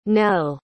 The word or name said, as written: nelle